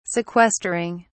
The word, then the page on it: sequestering: British English